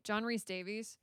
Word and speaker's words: Rhys-Davies: John Rhys-Davies.